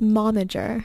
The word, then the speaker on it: momager: It's the right term is momager.